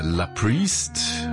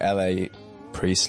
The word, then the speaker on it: LA Priest: Again, it's just like LA Priest, La Priest. There's not exactly a right way to say it.